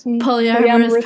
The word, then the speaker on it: polyamorous: Was this something like you always knew was something that you wanted, or did you conform to monogamy before deciding to become polymerist? How do you say it? Sorry, polyamorous person.